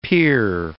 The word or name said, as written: pier